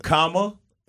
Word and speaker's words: Kamala: Just think of Kamala, and then La. – Kamala. – Kamala. – Like Tamala.